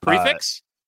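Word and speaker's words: prix fixe: But we didn't realize that for Valentine's Day, I'm going to stop short of pronouncing this because I know you say it the proper way that annoys everybody, but I'm going to stop short and let you actually say it. – Prefix? – Yes.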